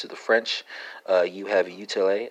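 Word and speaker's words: utilisés: It's going to be gebruikt, spelled G-E-B-R-U-I-K-T. French speakers translate the Dutch to the French. This is going to be utile, spelled U-T-I-L-I-S-E-S. That S is silent, that last S.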